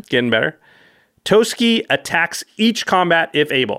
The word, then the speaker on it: Toski: She pronounces it Toski, so I think so.